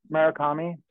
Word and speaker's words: Murakami: But because he has that in the museum, and you can see the massiveness of it, you know, or like I say the name wrong, Takami. Takami. Oh Jesus, Takashi Murakami. Murakami.